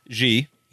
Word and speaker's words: Xi: I believe it's XI. – Yes. G.